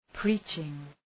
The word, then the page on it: preaching: ‘pri:tʃıŋ